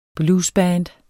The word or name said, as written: bluesband